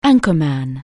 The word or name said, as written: anchorman